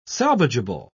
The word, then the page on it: salvageable: British English